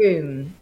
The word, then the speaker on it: Frome: Right, okay, so I'm going to start with your lovely life in, is it Froome or Frome? How do you say it? – Froome. F-R-O-M-E. – In Froome. – Yeah, like Broome.